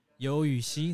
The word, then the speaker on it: Youyuxi: Well, I'm on Twitter as Yo Yuxi. That's my Chinese name. So spell it as Youyuxi.